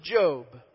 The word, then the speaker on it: Job: J-O-B. And don't ask me why it sounds like job. That's just how it's pronounced in that day and age. It's Job. All right?